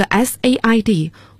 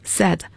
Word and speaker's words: said: 所以要读成 says，这个 said 过去式过去分词也要读成 said as in bed，而不可以读成 said，大家要注意这三个词的发音。